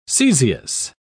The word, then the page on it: caesious: British English